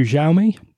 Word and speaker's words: Xiaomi: Xiaomi. – I'm going to say Xiaomi. – Xiaomi.